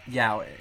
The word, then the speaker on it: yaoi: How do you say it? – Yaoi. – There you go.